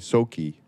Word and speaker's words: Tsoknyi: Uh, so there's a um, a great Rinpoche named Tsoknyi Rinpoche. Soki Rinpoche is how it's supposed to be pronounced, I think.